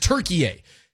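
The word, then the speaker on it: Türkiye: It's the way that Turkish people pronounce Turkey is Turkiye.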